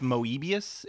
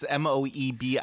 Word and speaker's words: Moebius: I think it's pronounced Moebius. It's Moebius.